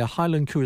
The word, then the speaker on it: Heilan Coo: Highland Coo is like Highland Cow, you know, Scottish pronunciation. But it's spelt H-E-I-L-A-N-C-O-O.